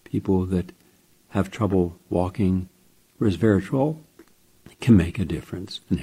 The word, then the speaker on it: resveratrol: From Harvard University, published in Frontiers in Physiology, red wine's resveratrol, trol, three syllables, resveratrol, that could help keep you strong and helps your bones.